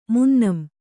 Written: ಮುನ್ನಂ

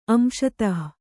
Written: ಅಂಶತಃ

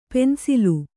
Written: ಪೆನ್ಸಿಲು

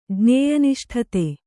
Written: ಜ್ಞೇಯನಿಷ್ಠತೆ

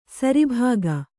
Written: ಸರಿಭಾಗ

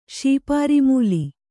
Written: ಶೀಪಾರಿಮೂಲಿ

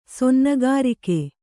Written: ಸೊನ್ನಗಾರಿಕೆ

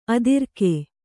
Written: ಅದುರುಗ

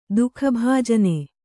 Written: ದುಃಖಭಾಜನೆ

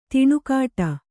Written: ದನಿಗೊಡು

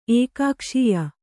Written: ಏಕಕೋಶೀಯ

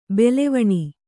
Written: ಭುಲ್ಲವಣೆ

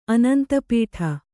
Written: ಆನಂದಪಡು

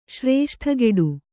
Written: ಶ್ರೇಷ್ಠಗೆಡು

